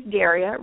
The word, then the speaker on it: Daria: My website, my name is Daria, rhymes with area.